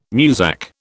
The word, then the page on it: Muzak: British English